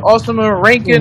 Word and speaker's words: Ousama Ranking: And like, y'all know how to pronounce it. Ousama Ranking. – You Ranking said it. You said it right.